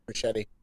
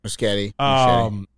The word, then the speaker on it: Muschietti: But then again, the comments from the director, Andy Muschietti. – Is that how you pronounce it? Muschietti? – Muschietti.